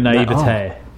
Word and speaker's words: naivete: Yeah, a reflection of your naivete. That's how you say it.